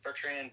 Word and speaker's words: Bertrand: Adam Bertrand, A-D-A-M, Bertrand, B-E-R-T-R-A-N-D.